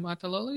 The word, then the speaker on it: Niumatalolo: Did I say that right? I said that right. – Niumatalolo, you did all right.